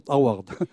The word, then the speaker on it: Harvard: Harvard.